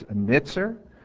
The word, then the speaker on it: netzer: This word for branch is netzer. It's spelled N-E-T-Z-E-R.